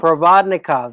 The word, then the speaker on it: Provodnikov: I would have made Provodivnik. How do you pronounce that? I forget. – Oh, is that the Paul Jones? – Yeah. – Provodnikov? – Provodnikov. There you go.